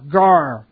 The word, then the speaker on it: gar: G, and then this is silent. A, R, gar.